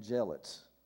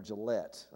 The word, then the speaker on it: Jillette: Some of you are familiar with a man by the name of Penn Jillette, or Gillette, I think is the way you pronounce that.